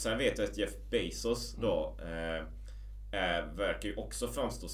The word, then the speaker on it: Bezos: Ett lite angränsande tema så här egentligen, men jag läste ju också Amazon och Jeff jag vet inte om man uttalar Jeff Bezos efter, ja. Men det är Bezos eller? – Bezos.